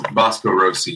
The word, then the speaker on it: Vasco Rossi: Vasco Rossi, yeah.